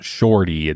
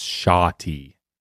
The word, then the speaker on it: shawty: It's not shorty, it's shoddy. – What, do you need a lesson right now? – What's going on? – It is spelled S-H-A-W-T-Y.